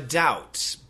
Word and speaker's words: doubt: Something is beyond a shadow of a doubt. Beyond a shadow of a doubt. Beyond, B-E-Y-O-N-D, a shadow, S-H-A-D-O-W, we've been talking about shadow, of a doubt, D-O-U-B-T. The B is silent.